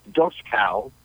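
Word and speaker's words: Dočkal: I think the the player that they brought in though, this number ten, uh, it, doesn't look like it, but it's pronounced Doskal. It's D O C K A L.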